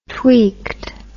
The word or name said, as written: tweeked